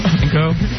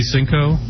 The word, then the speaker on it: cinco: Kinko? – Do what? – I said, was Kinko supposed to be Cinco? – Cinco.